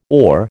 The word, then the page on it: OR: American English